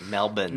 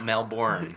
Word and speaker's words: Melbourne: That's how you say it, Melbourne. Melbourne. Melbourne. It's not Melbourne.